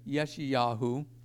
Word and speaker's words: Yeshayahu: They also, you'll see Isaiah is Yeshayahu, which is the salvation of Yahweh.